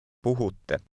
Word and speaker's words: puhutte: Puhutte. – Pay close attention to the double T sound at the end.